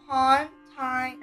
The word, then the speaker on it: Pontianak: It's pronounced Pontianak.